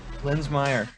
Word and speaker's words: Linsmayer: Linsmayer, yep.